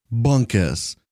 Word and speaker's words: Bunkus: For example, Peter, P-E-T-E-R. Now, don't forget to wait for the beep. – Bunkus, B-U-N-K-U-S, Bunkus.